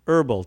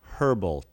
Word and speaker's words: herbal: że chociaż słowo zioło, herb, pisze się z literką H na początku, nigdy się jej nie wymawia. Powinieneś więc powiedzieć herbal tea, a nie herbal tea.